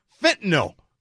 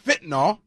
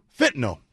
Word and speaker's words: fentanyl: it's pronounced fentanyl. Get it right. Fentanyl. Not fentanyl. Fentanyl. Appreciate that. – They said it rhymes with windowsill.